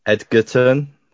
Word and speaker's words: Egerton: You wanted think that's how you pronounce his name. – Yeah. – Egerton. Yeah.